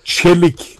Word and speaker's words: Çelik: Qualcuno dice, si dice Çelik. – Sì, bravo, esatto.